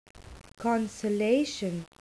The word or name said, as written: consolation